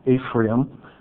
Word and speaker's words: Ephraim: To the Board of Supervisors here in the chambers, my name is Samuel White Ephraim. That's spelled E-P-H-R-A-I-M.